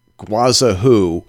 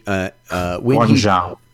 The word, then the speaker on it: Guangzhou: State Department Security Officer Mark Lindsay used to work in the Council of, can't even pronounce this, Guazahu in China when he... – Guangzhou.